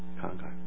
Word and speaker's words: 慷慨: 我的发音有没有错？慷慨对不对？啊，是慷慨吧？慷慨。